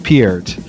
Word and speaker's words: Peart: It's just Peart.